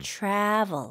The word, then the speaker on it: travel: 아 이거 발음 주의하셔야 될 것 같아요. 그 문제가 생겼다라고 할 때 trouble라는 게 있잖아요. – 네. – 이 trouble, travel 발음이 다르죠? – Yeah, mm-hmm. that's right. 여기는 travel, mm-hmm. travel. – V이기 때문에 입술이 붙어서도 안 되고 그리고 입이 좀 많이 벌려진 것 같으네요.